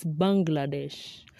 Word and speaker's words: Bangladesh: The second word is Bangladesh. I have always thought it's Bangladesh. Not until a few months ago. Hmm? Hey, it has an N. It's Bangladesh.